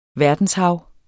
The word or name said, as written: verdenshav